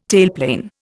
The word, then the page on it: tailplane: Indian